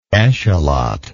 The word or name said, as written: eschalot